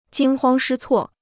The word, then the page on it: 惊慌失措: jīng huāng shī cuò